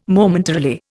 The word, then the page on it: Momentarily: Indian